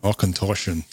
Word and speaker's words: Auchentoshan: Well, I want to hear pronounce it properly in the Scottish tongue. – Auchentoshan. – There we go. – Does sound better Scottish, doesn't it? – Yeah, does sound better than Scottish.